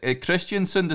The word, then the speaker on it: Kristiansund: And is it Kristiansund? Is that how you pronounce that? Is that where you were born? – Yeah, that's right.